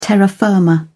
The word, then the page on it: terra firma: British English